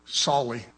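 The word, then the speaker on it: Sully: You know, it's like, how many of you heard of this pilot named Scully before he put an airplane down and saved thousands of people? Did I pronounce his name wrong? Is it Scully? Solly.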